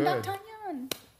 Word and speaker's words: D'Artagnan: It's the way you pronounce it. – That's how it's pronounced. – D'Artagnan. – In English.